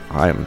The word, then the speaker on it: Haim: Haim. Song's called The Wire. It was the remix by Tourist. – Yeah, I don't know how H-A-I-M becomes Haim, but that's how they pronounce it.